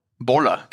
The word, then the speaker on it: Bolla: Boller. – Genau. Bendegusch, bitte keine Garantie nehmen, dass es richtig ausgesprochen habe, aber Boller, B-O-L-L-A, wird übrigens richtig sein.